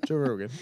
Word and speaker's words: Joe Rogan: Joe Adolf Rogan. Hitler? What am I saying? Joe Rogan? – Joe Rogan.